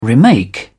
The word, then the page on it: remake: British English